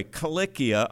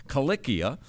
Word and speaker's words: Cilicia: So here he is speaking like this, and he says, I'm from Tarsus in, by the way, Cilicia. I pronounce it that way because these are kappas in Greek. They're k's in Greek. Cilicia.